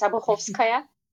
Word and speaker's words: Obuhovskaya: You did it right, actually. It's Obuhovskaya.